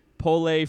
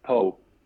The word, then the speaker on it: Pau: But you started with Pole, France. Is that how you say it? – It's Poe. – Poe. – Poe. Just like Pau Gasol.